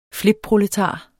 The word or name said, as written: flipproletar